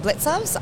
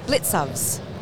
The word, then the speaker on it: Blicavs: How do you say your last name? Blicavs. Blicavs? – Yeah. How is it spelled? – B L I C A V S.